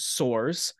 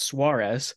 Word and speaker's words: Soares: It's spelled S O A R E S, and that's a Portuguese name that's pronounced Suarez. And I'm Portuguese, so I look at that name. It's like that says Suarez.